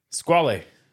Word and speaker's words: Squale: Squale. – Ooh-hoo. We said it right. – Squale. We did say it right.